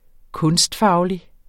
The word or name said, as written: kunstfaglig